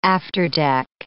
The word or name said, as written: afterdeck